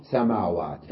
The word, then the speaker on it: سماوات: سابعا سماوات، نلفظ بعد الميم ألف سما ولا نكتب هذه الألف، سماوات، قال جمع سماء، إذن نلفظ الألف بعد الميم وقبل الواو ولا نكتبها. ومنهم من يكتبها في غير القرآن الكريم، إذن بالقرآن دائما جاءت سماوات، سماوات هكذا تكتب لكن تلفظ سماوات، فهذه الألف لفظت وما كتبت.